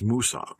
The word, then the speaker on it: Mwsog: It is spelled M-W-S-O-G, which translates to English in moss. But if you say it in Welsh, it is Moosog.